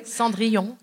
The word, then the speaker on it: Cendrillon: Cendrillon, yeah.